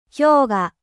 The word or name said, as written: glacier